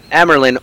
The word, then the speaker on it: Amyrlin: Once they're in Amyrlin, how do you guys pronounce it? – I say Amyrlin.